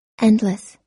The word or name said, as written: endless